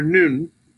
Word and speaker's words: Nun: And so the most beautiful thing I see is that Psalm 119, 105, falls under the letter N or Nun, which is the way it's pronounced in Hebrew.